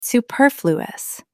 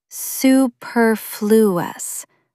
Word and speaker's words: superfluous: Stress the second syllable. Superfluous.